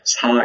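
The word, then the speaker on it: Sa (士): Since you're a sinologist, I may as well mention that the character ideograph is pronounced Sa in Korean, sure, in Chinese as in literati, or the sajok, I guess the family or the group of literati.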